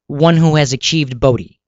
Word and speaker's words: Bodhi: He was Siddhartha, sat in the fig tree, and achieved Bodhi, so then he became Buddha. So that's why it's the silent H, BODHI.